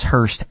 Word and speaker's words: Herst: Last name is Hurst, H-E-R-S-T. First name, Jonathan, mm-hmm. J-O-N-A-T-H-A-N.